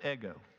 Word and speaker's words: ego: In Greek, it's pronounced ego, we think, and not like the waffle, okay?